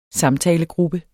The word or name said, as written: samtalegruppe